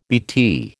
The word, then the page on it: BT: American English